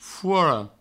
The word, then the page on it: fora: fouoro